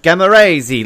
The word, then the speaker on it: Giamarese: And to get things started, let me introduce myself by saying my name is Ben, G-I-A-M-A-R-E-S-E, Gamma Raisi, like it sounds.